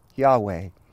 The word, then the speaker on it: Yahweh: We pronounce that today Yahweh.